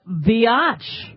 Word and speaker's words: biatch: I'm telling you that Senator Chuck Schumer called a flight attendant a biatch. Rhymes with which?